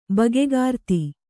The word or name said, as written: ಬಗೆಕಾರ್ತಿ